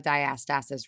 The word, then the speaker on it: diastasis: Did I say that correctly? – Honestly, I've only heard a few people say it. I say diastasis. – Diastasis. All right. Well, 10 points for the non-pregnant human over here.